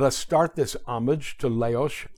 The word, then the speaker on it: Leoš: Leoš.